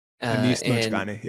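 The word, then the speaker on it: Anis Mojgani: And that jumped out early in the book for me when you mentioned the line that the poet, I may have the pronunciation wrong and you correct me on this, Anis Mojani? – Anis Mojani, yeah.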